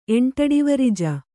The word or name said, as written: ಎಂಟಡಿವರಿಜ